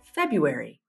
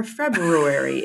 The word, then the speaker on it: February: Or February, if you want to say it like it is pronounced, as Mac and I were just doing for some reason off air, or as it's spelled, I should say.